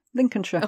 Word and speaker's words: Lincolnshire: Did I say that right? – Yeah, Lincolnshire.